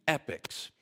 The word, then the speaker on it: epoch: I'll use James Dunn's word, two epics. Not epic, E-P-I-C, like an epic tale or story. E-P-O-C-H. In American English, we pronounce them the same way, I think.